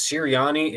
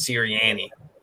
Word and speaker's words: Sirianni: Just for the Sirianni pronunciation, I think it's Sirianni if you're normal, and then if you're from Delco, it's Sirianni.